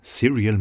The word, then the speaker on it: Serial: William Parker und Ellen Christie mit Baseball, einem Track von dem neuen Album Serial Music, ausgesprochen, aber nicht geschrieben wie die serielle Musik, sondern wie die Cerealien, also das Müsli, das, was man als Kind jeden Morgen in sich reinschaufelt, um Kraft für den Tag zu haben.